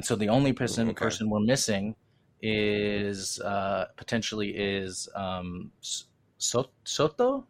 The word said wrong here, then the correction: Sota Kitahara: Yeah, Sota Kitahara.